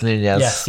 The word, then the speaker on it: Nunez: Yeah, I think as well, I think it'd be really interesting to see how Nunez and because that's how you say it, it's Nunez.